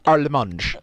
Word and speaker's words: Harmange: Pauline Armange, H-A-R-M-A-N-G-E. If I know anything about the French language, they don't care about the letter H, so it's just Armange.